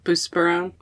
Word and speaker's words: buspirone: I'm on buspirone. Am I saying that right? – Yes, buspirone.